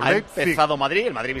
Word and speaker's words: Leipzig: Va a jugar el Leipzig o Leipzig, ¿cómo se dice, Pedro Martín? – Leipzig, como se escribe. – Muy bien, el otro día oí a un moderno que lo decía de otra manera. – Leipzig.